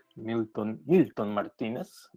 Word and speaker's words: Milton Martinez: I'm your host, Milton Milton Martinez. That's how you say it in Spanish.